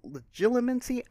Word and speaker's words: legilimency: It is skilled at legilimency. I have no idea how that word's supposed to be pronounced. – I think you got it right. Legilimency.